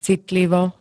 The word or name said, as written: citlivo